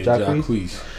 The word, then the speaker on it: Jacquees: Yeah, Jacquees.